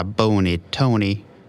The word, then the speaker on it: Bony Tony: Oh, my name is, I guess I go by Bony Tony. – Bony, can you spell that for me? – Just like you spell B-O-N-Y-T-O-N-Y.